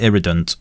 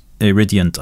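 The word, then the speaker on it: Iridient: So first of all, I call it Iridant. Iridiant, I think, is the correct pronunciation. Iridiant. And the answer is no, I've never used it.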